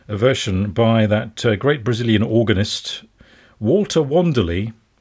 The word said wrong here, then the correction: Wanderley: or as I discovered recently, his surname is actually pronounced Vanderlei.